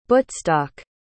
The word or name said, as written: buttstock